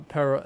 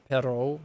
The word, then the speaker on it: Perraud: How do, would you pronounce that? – Perraud? Uh, where am I looking? – Southampton. – Uh, Perro. – It would top. be Perro. Yeah, something like that.